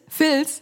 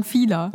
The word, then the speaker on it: Fila: Also diese Sportbekleidungsmarke, wo es auch Sneaker gibt und so weiter, die heißt nicht Filz, sondern Fila.